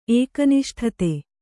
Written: ಏಕನಿಷ್ಠತೆ

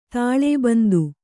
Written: ತಾಳೇಬಂದು

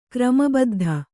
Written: ಕ್ರಮಬದ್ಧ

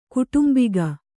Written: ಕುಟುಂಬಿಗ

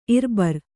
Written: ಇರ್ಬರ್

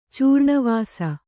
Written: ಚೂರ್ಣವಾಸ

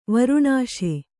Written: ವಾರಣಶಿ

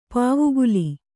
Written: ಪಾವುಗುಲಿ

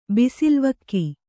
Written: ಬಿಸಿಲ್ವಕ್ಕಿ

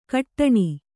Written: ಕಟ್ಟಣಿ

